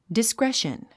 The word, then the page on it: discretion: diskréʃən; ディスクレション